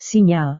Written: sinyal

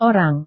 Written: orang

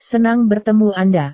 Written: senang bertemu anda

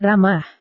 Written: ramah

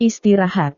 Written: istirahat

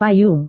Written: payung

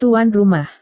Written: tuan rumah

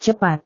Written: cepat

